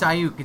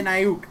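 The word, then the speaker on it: Aiyuk: No, it's Ayuk. It's Brandon Ayuk.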